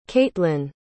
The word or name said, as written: kaitlin